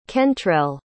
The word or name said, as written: kentrell